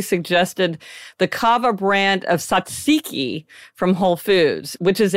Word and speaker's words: tzatziki: Yeah, tzatziki.